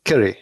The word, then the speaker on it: Kiri: I thought no, they named K-I-R-I, but the way they pronounce it, it's like Kitty.